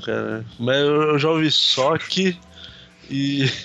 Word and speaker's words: Sochi: Alguém sabe como é que fala o nome desse lugar? Acho que é Sochi mesmo.